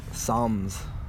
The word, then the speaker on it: Psalms: Psalms. – I did not know how to read that. Psalms, I was like, Psalms, doesn't it? But the P is silent.